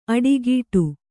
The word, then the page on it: ಅಡಿಗೀಟು: Kannada